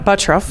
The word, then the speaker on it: Butruff: I'm here with Sarah Buttruff, right? – Yep, Buttruff. – Buttruff, okay, I'll say it right.